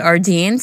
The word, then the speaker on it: Ardene: How do you pronounce Ardeen. – It? Ardeen. I was in there with Aaliyah a while ago. Ardeen. A-R-D-E-N-E. Ardeen.